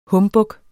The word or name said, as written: humbug